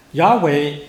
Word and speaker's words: Yahweh: For example, the Hebrew original word had two syllables, and Yahweh also has two syllables, but Yehovah has three syllables. – Yeah. 比如说那个在希腊原文里面雅伟只有两个音。而中文的那个雅伟也只有两个音，但是耶和华却有三个音。 – Now we see that Yahweh is recognized by scholars as the personal name of God.